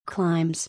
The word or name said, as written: Climbs